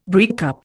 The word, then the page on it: breakup: Indian